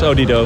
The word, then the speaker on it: Odido: Klopt, het is Odido.